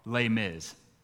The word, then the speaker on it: Les Mis: It looks like it's spelled Les Miserables. Apparently, French people don't like all the letters, and they just say like the first few letters and then mumble the rest, right?